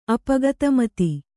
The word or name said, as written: ಅಪಗತಮತಿ